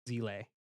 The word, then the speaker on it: Seele: Is that the It's spelled S-E-E-L-E, but it's pronounced Zile.